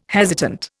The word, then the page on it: hesitant: Indian